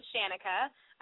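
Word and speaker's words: Shanica: It's at I am Shanika.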